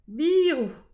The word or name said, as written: birou